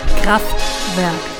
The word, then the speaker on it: Kraftwerk: Kraftwerk. – Thank you. Song's called Ruckzuck. – Ruckzuck. – That's what I said. And, you know, elements of that song remind me of the song Autobahn by Kraftwerk.